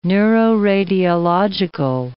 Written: neuroradiological

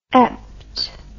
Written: ept

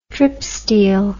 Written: tripsteel

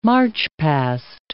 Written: march-past

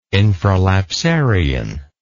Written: infralapsarian

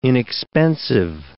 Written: inexpensive

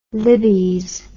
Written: libbys

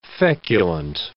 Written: feculent

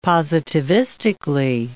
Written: positivistically

